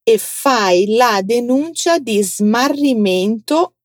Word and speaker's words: smarrimento: Tem a letra S muda, então atenção, vem comigo. Mento. Smarrimento. Vem de smarrire, que é perder. Agora fala comigo. Denuncia di smarrimento.